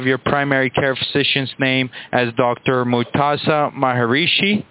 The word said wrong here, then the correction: Maheshwari: Or how do you pronounce the last name? – Maheshwari, thank you, ma'am.